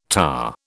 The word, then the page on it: tahr: British English